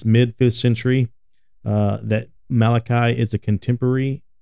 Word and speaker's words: Malachi: Some might pronounce it Malachi. But no. that is incorrect. It is Malachi.